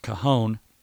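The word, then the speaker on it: cajón: And so if you don't know what a cajon is, and by the way, it's spelled with a J in there. It looks like Cajun. It looks like if you're reading it, it looks like it's spelled Cajun, but it's pronounced cajon.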